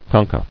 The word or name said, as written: Concha